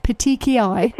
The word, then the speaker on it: petechiae: So how is it pronounced? Petechiae. – Petechiae.